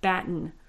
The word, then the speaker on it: batten: And this is found typically in the southern Appalachian region, and it is a shortening of the form batten. Batten. I kind of swallowed that second syllable there. That's just my variety of English, but you may be more familiar with that term.